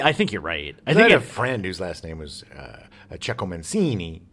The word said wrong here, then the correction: Ciccio Mancini: but it was Ciccio Mancini. – Ciccio Mancini. Yeah. That actually makes sense, and I think that we've always just said it wrong.